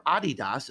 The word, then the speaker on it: Adidas: I have a friend who is a brand manager for Adidas or Adidas, as it's pronounced in most parts of the world.